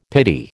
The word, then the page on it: pity: American English